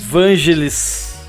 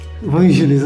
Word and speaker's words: Vangelis: Não sei como que a gente pronuncia isso. Teve com o Crystal Castles. – Vangelis. – Vangelis.